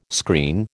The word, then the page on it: screen: American English